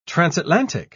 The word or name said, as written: transatlantic